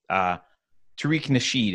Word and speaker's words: Tariq Nasheed: And the guy, sorry, I mispronounced his name. It's Tariq Nasheed is the guy who I was thinking of on Twitter, who posted this.